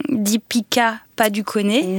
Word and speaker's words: Deepika Padukone: Deepika Padukone. – Oui. – Est-ce que c'est la bonne façon de le prononcer ? – Oui.